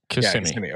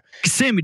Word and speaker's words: Kissimmee: They've been kind of throwing their own events, and they've got this, they've really built this venue up, which is in, it's, you pronounce it, because I say it wrong every time. It's Kissimmee, yeah? – Kissimmee. – Okay. – Kissimmee, dude.